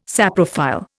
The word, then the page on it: saprophile: Indian